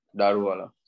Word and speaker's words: Daruvala: What is the correct way to pronounce it? – Daruvala.